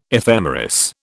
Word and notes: ephemeris: British English

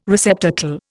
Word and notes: receptacle: Indian

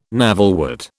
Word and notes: navelwort: British English

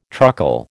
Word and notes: truckle: American English

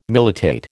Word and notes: militate: American English

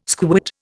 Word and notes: squit: Indian

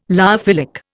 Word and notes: lyophilic: Indian

